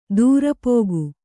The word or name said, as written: ದರ್ಪಕ